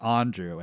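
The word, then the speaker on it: Aundrew: That's A-U-N-D-R-E-W-L-E-E. Andrew was like a joke that I made in high school where like, the teacher said my name and I said, oh, it's pronounced Andrew, and a few people laughed and gave me a heart attack.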